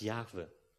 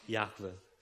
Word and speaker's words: Jahwe: Er wird mit diesen vier Buchstaben geschrieben, J-H-W-H. Wir nennen ihn, beschreiben ihn meistens dann als Jahwe, weil wir denken, dass das so ausgesprochen wird, obwohl es keiner genau weiß. Jahwe.